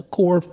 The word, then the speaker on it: Corps: So it's a core, like the Peace Corps or the Marine Corps, it's spelled the same way.